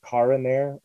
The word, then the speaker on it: Cara Neir: what's the correct pronunciation of Caraneir? – To be honest, I have it to where it's kind of open to interpretation. It can be either Cara Neir or Cara Neir.